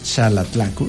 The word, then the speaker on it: Xalatlaco: Está aquí en la cabina del presidente municipal de Xalatlaco, porque así se pronuncia Xalatlaco. Muchos decíamos Xalatlaco, pero Xalatlaco ya nos lo precisó.